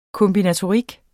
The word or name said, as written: kombinatorik